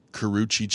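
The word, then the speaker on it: Karrueche: I think it's pronounced Karuchi Chan.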